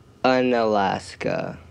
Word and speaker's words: Unalaska: Unalaska.